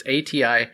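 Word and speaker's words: Atiyeh: All right, next up I have Demigod number 2, written by Ron Mars, art by Andy Smith, colors by Michael Ataya? I never know how to pronounce his name. It's Ataye.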